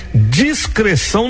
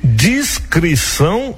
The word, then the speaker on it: discrição: Agora cuidado, hein, gente, tem discreção, não existe isso aí. Se você falar discreção, isso aí, essa palavra realmente não existe. E acontece muitas vezes a gente pronunciar isso aí inadvertidamente, a gente acaba pronunciando, mas é errado, essa palavra não existe. Existe discrição e descrição.